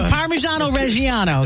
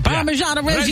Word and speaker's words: Parmigiano Reggiano: Specifically Parmigiano Reggiano, because it's the combo makes it and you got to say it like that too. Parmigiano Reggiano. – You got to say it like the old country.